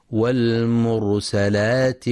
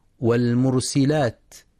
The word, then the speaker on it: والمرسلات: في بداية سورة المرسلات: بسم الله الرحمن الرحيم، والمرسلات عرفا. ينتبه القارئ لفتح السين فلا يقرأ والمرسلات، وينتبه أيضا لتفخيم راء والمرسلات وعرفا، لأنها ساكنة وما قبلها مضموم، فحكمها التفخيم.